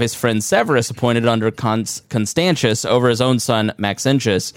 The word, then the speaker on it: Constantius: Now, Constantius, am I pronouncing Constantius? – Constantius.